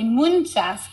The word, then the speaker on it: muchas: Instead of muchas gracias, we have an N in the word muchas. So we say muchas gracias.